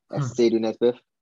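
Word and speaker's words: Asiedu-Nesbeth: If you want to see me on LinkedIn, that's with my name, Myles, M-Y-L-E-S, Sadie Nesbeth, A-S-I-E-D-U hyphen N-E-S-B-E-T-H.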